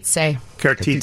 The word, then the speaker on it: Cartizze: So it's Cartizzi? Mm-hmm. Is that how you would pronounce it? Cartizze.